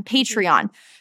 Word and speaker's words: Patreon: So something that I'm loving is Patreon. Am I saying that right? – Patreon.